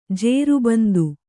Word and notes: ಜೇರುಬಂದು: jēru bandu

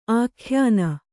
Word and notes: ಆಖ್ಯಾನ: ākhyāna

